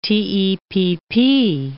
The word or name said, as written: TEPP